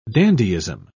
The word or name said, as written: dandyism